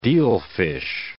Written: dealfish

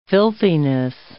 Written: filthiness

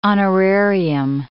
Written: honorarium

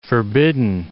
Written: forbidden

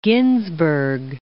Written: Ginsberg

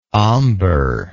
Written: omber

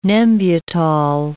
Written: Nembutal

